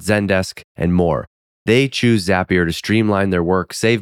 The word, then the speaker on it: Zapier: That's the reason why Zapier rhymes with happier. Bet you didn't know that. Now you know how to say it the right way, too.